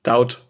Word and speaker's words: Doubt: Auf jeden Fall hat sie mir Daubt empfohlen. Oder Daubt, D-O-U-B-T. – Daubt ausgesprochen.